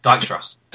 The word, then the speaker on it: Dijkstra: Is it Dijkstra? – Dijkstra. – Dijkstra. Thank you. That's it.